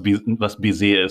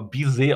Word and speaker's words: Baiser: Guck mal, ich habe nämlich, es wird ja Baiser geschrieben, es wird aber Baiser ausgesprochen.